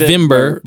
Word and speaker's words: Wibmer: It's pronounced Wimber, but it's W-I-M-B-E-R. – No, B-M-E-R. – B-M-E-R, okay, sorry. – Yeah, so W-I-B-M-E-R. Fabio Wimber.